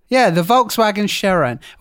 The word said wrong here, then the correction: Sharan: Well, Sharan.